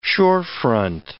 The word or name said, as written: shorefront